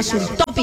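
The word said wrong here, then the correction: Xultophy: insulina Sultopi. – Sultofi. – Sultofi, eso, Sultofi. Usted que es prácticamente alemán, pronuncia mejor que yo.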